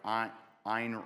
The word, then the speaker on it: Ayn: Atlas Shrugged by Ayn Rand. It's pronounced Ayn, but it's spelled A-Y-N.